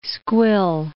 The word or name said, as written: squill